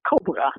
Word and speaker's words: cobra: And you can take cobra, you know, cobra, the snake. So that's the second syllable in English is reduced. It's just a schwa. And exactly the same word occurs in Dutch, except you pronounce it cobra, you know, and the first syllable has primary stress and the second syllable has secondary stress and the four vowels, it's not schwa.